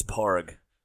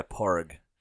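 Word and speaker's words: Pádraig: By the way, everyone, that's how you pronounce Padraig. It's Parg. In Gaelic, yeah, Parg. You don't say it. That's Paddy. – Right. – That was Saint Patrick's name. It's right. Parg.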